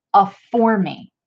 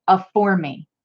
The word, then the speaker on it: aphorme: The Hebrew word for occasion is aphormi. I'll put it up on the screen so that you can see it. It's spelled A-P-H-O-R-M-E. For those of you who are listening, aphormi, A-P-H-O-R-M-E.